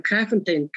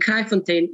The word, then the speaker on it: Kraaifontein: But our address is 89 Horrock Street, Peerless Park East in Kraaifontein, Cape Town, South Africa.